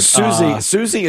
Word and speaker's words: Siouxsie: Susie's spelled very weird. – Susie is spelled S-I-O-U-X-S-I-E. – I thought Banshees was going to be the harder one here, but no, it's Susie.